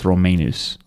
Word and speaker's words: Romanus: It had like a long bar over the A, so it's Romanus.